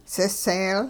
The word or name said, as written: siseel